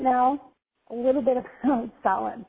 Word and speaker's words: Samhain: And it's definitely pronounced Samhain. Kind of like S-O-W-I-N.